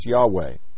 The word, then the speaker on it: Yahweh: The pronunciation can never be certain, since early Hebrew had no vowels, only consonants. Though evidence from several sources, such as early Greek transliterations, point to the pronunciation as Yahweh.